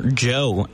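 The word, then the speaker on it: Djo: That was End of the Beginning by DGO, by Joe, as I recently discovered his name is pronounced.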